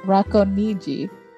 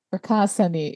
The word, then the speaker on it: Racconigi: So Umberto II was born in, the correct way to say this is Racconigi, but in the English way, American way, it's Racconigi.